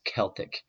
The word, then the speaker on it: Celtic: It's a hard C or a K sound. It's Celtic. It's Celtoi, not or as the Romans brought it into the Latin language, Celtai. So it's not the Celtic. The soft C comes later, and it's good for the basketball team.